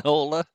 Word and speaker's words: Enola: Enola.